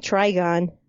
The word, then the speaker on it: Trigon: A demon named Trigon.